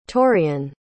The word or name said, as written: torreon